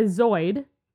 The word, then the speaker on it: zooid: So, a zoid... – They're different than noids. – Absolutely. A zoid, Z-O-O-I-D, zoid, yes, I'm saying this correctly, is an individual bryozoan, which has an outer sleeve-like structure and a mass of organs, okay?